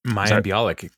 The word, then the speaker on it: Mayim Bialik: Mayim Bialik? – Mayim Bialik, current co-host of Jeopardy.